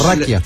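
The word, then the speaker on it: rakia: ¿Raquia? – Sí, raquia, que se escribe R-A-K-I-A o R-A-K... Y J.A., raquija.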